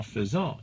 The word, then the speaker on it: faisant: As for en faisant, you'll note that the AI is pronounced as a schwa, something like le or the in English. Now to the vocabulary, page 127.